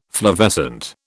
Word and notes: flavescent: British English

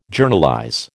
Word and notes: Journalize: American English

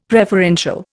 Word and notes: preferential: Indian

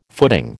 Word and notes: footing: American English

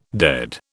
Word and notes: dead: British English

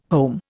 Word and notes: home: Indian

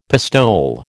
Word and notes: pistole: American English

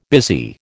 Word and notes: busy: American English

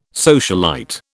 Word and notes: socialite: British English

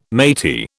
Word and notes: matey: British English

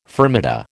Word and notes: fermata: American English

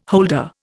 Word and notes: holder: Indian

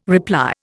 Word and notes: reply: Indian

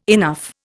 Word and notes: enough: Indian

